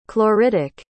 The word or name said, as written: chloritic